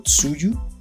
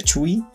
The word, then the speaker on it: Tzuyu: se escribe T-Z-U-Y-U, yo siempre lo había pronunciado Tsuyu, resulta que se pronuncia Chui,